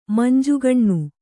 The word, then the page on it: ಮಂಜುಗಣ್ಣು: Kannada